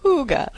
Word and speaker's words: hygge: it hygge? Hygge? – Hygge. – Hygge, thank you.